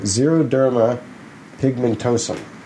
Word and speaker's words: xeroderma pigmentosum: Yeah, the character in Fear Nothing has a condition known as, if X is pronounced X, it's I think it's Z, pronounced like a Z, so it's probably like xenophobia. Xeroderma pigmentosum.